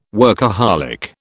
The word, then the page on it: workaholic: British English